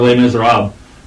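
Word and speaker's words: Les Misérables: But for Les Miserables, I pronounced it correctly, right? – Yes, you did.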